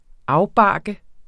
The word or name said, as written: afbarke